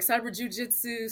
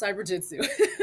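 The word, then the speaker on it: Cyberjutsu: They're like, cyber jujitsu, cyber jutsu. I'm like, it's just cyber jutsu.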